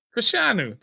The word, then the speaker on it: Fashanu: Fashanu, that's not hard.